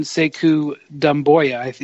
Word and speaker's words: Sekou Doumbouya: So to go back to the board, Mike, you mentioned Sekou Doumbouya. Is that am I pronouncing it correctly? – Yeah, close enough.